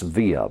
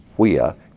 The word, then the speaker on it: Via: Looks like it's via, but it's pronounced via in classical Latin.